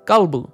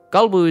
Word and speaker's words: Kalbu: Kalbu. Kalbu is the Yaru word for up or on top.